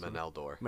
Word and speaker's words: Meneldor: And I already forgot how to say this last Meneldor.